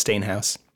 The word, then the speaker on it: Steenhuis: It's spelled Steenhuis. No one thinks it's Stainhouse.